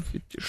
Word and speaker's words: фетиш: Фетиш. Феномен фетиша. – Угу, да. – Да-да-да. – Феномен. – Феномен, да, греческое происхождение. – Да. – Поэтому ударение на второй слог. А фетиш французское, поэтому на последний.